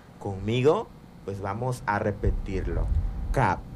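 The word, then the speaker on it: kab: Que en lengua yocotán se pronuncia cap.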